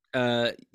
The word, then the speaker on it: Gijón: He's also been the assistant manager of Sporting Gijón, is it called? Gijón? – Gijón. G-I-J-O-N. Yeah.